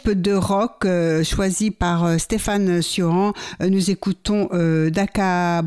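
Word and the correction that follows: DakhaBrakha: Braha. Est-ce que je prononce bien? Non, mal certainement. – Dacha Braha. – Voilà, vous n'êtes pas loin. – Je suis pas loin. Alors, Dacha Braha.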